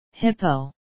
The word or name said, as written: hippo